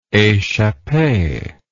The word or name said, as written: echappee